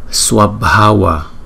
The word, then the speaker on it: Swabhava: Nhưng cái B có chữ H thì chúng ta đọc là B. B. Cái hơi nó mạnh, từ trong miệng chúng ta đưa ra nó mạnh. Cho nên là chữ này đọc là Swabhava.